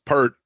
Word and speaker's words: Peart: How do we say it again? – Peart. Ask Eddie Trunk. He agrees with me.